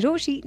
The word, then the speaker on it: Rochie: मेरा नाम है मिर्ची रोशी, नाम के स्पेलिंग है ROCHIE and I super love you.